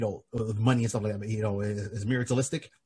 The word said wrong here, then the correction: materialistic: Oh my God, I'm mispronouncing. – Materialistic.